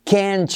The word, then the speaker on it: can't: Pero si miras la transcripción fonética que te pongo con nuestro sistema fonético de UTalk, verás que el can't, la T no se pronuncia. Pero el truco es pronunciar como can, pero acentuando la palabra, ¿vale? You can't just look away.